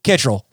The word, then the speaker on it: Kittrell: I want to welcome to all of you, Lou Kittrell.